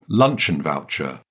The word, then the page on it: luncheon voucher: British English